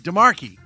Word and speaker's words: DeMarche: We had Marla DeMarche. No, it's DeMarkey, because it's the C is the H is silent.